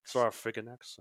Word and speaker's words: Saurophaganax: Saurophaganax. Well, it's like, it's the same terminology that sarcophagus comes from, so Saurophaganax.